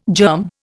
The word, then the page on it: germ: Indian